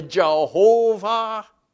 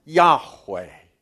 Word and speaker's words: Yahweh: Traditionally, Jehovah, probably not the right pronunciation. We're not sure. Convention these days has it as Yahweh. That's artificial as well, but it's the best we can do.